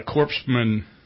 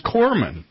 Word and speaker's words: corpsman: After he said it like the third or fourth time, I think what he was trying to say was corpsman. You know, a hospital corpsman in the Navy, you know, C-O-R-P-S-M-A-N. – The S is silent.